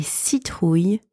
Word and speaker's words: citrouille: J'ai acheté une citrouille pour Halloween. La citrouille est orange. The pumpkin is orange. La citrouille est orange. And last one, on va sculpter des citrouilles ce soir.